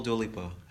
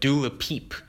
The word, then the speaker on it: Dua Lipa: And I hope I'm not pronouncing her name wrong. – Oh, it's funny because Wendy Williams started this trend of Dula Peep. So you're fine with her name pronunciation.